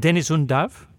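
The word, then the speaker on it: Deniz Undav: Both goals this weekend coming from the German striker, how should this be pronounced, Dennis Undav? – Yes, that's right. That's perfect pronunciation.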